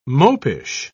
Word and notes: mopish: British English

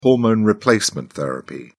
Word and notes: hormone replacement therapy: British English

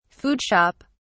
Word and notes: food shop: British English

